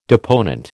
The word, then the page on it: deponent: American English